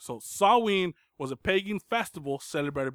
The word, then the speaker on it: Samhain: It's spelled Samhain, yeah.